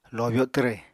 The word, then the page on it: lobyo twre: Apatani